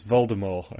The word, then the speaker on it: Voldemort: It's Voldemort, according to J.K. Rowling yesterday. – The T is silent?